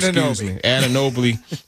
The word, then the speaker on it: Anunoby: I can never pronounce his name. Anunoby. I was going to put that L in there for some reason.